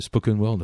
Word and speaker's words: spoken word: spoken world, on dit ça, le spoken word.